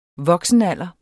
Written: voksenalder

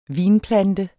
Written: vinplante

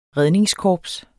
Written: redningskorps

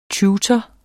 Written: tutor

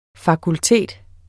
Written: fakultet

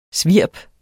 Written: svirp

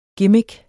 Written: gimmick

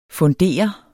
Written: fundere

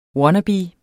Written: wannabe

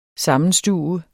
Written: SAMMENSTUVES

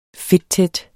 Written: fedttæt

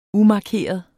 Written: umarkeret

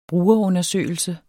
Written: brugerundersøgelse

